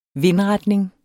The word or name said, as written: vindretning